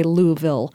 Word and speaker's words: Louisville: And I'm from Ohio and South Florida, so I can't say Louisville quite correctly. There's an extra syllable of vowel in there. Can you say it for me one more time? – Louisville?